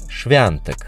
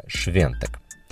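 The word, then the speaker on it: Świątek: По-польски она пишется «Швяток», но произносится она именно «швянтек», хотя есть еще и версия «швентек» или «швемтек». Так вот,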